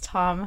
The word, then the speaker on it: Thom: Am I saying that right? Thom? Or do we just say Tom? – It's Tom, honey.